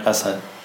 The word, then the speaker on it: LaSalle: Habe ich das richtig ausgesprochen? LaSalle? – Ja, LaSalle.